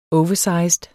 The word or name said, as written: oversized